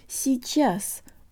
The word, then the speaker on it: сейчас: guys, today you'll learn how to pronounce the word сейчас or now. The ye sounds like an e here.